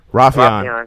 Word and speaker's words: Rafion: Rafion. That's close to how it's pronounced. And I think I was putting more emphasis on the E.